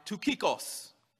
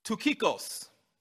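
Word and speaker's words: Tukikos (Τυχικός): Their names are Tukikos. I will try to pronounce them in the Greek as they would have been. Tukikos.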